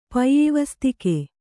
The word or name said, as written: ಪೈಯಿವಸ್ತಿಕೆ